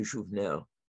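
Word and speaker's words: Jouvenel: What about, I'm terrible at pronouncing him, is Jovenel? – Yeah, Bertrand de Jovenel.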